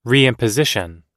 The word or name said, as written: reimposition